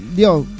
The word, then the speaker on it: Lyon: ¿Cómo se dice? ¿León o Lyon? – León. – León francés.